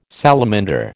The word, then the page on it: salamander: American English